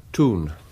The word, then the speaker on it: Thun: To a bank account in Thurn. Is that the way you pronounce it? T-H-U-N? – Thurn. It's in Switzerland, not far from Bern.